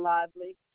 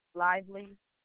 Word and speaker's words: Lively: Najee Lively. – Can you spell that first name for me? – N-E-I-G-H-A-I, Lively, L-I-V-E-L-Y.